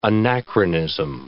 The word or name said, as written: anachronism